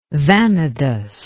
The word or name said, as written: vanadous